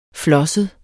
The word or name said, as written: flosset